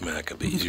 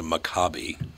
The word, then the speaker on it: Maccabees: It's Maccabees. You're absolutely right. Or some people pronounce it Maccabi, but...